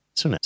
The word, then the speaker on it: Sunak: How do you pronounce his name? I can't ever remember. – Sunak.